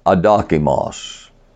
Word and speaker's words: adokimos: Alpha is pronounced ah. adokimos, which means not dokimos.